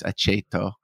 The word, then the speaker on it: Aceto: So CH is if it's followed by a vowel. So it's aceto.